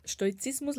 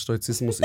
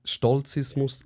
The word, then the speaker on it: Stoizismus: Ähm, Ruby, befolgt Stoizismus so fest, dass sie nicht mal gewusst hat, was Stoizismus ist. – und das Wort Stolzismus äh, ausgesprochen hat.